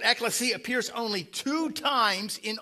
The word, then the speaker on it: ekklesia: It's actually a Greek word. Some of you may know that. Pronounced ekklesia, the Greek word for church.